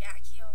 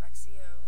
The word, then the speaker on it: Accio: That's what the movie said, like, Accio instead of Axio.